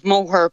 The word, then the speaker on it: Moher: Earlier I asked how should we pronounce the Cliffs of Moher? – Mary, I'm going to pronounce it in the Clare dialect. So it's the Cliffs of Moher, is how the locals would describe the name of the cliffs.